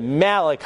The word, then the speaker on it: Malachi: So, all right, we'll get into Malachi. That's how you're supposed to pronounce it.